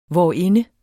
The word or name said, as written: hvorinde